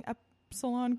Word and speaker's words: Epsilon: Then there was another incident at Yale where Delta Kappa Epsilon, if I'm pronouncing Epsilon correctly. – Yes, you are.